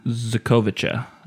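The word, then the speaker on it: Xocoveza: I believe it is pronounced... – Zakovica? – Zakovica. – Zakovica? – I believe.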